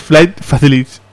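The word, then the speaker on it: Flight Facilities: Es una pronunciación muy chunga, es Flight Facility.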